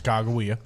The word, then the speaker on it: Sacagawea: I mean, but we have clarified that it is pronounced Sacagawea.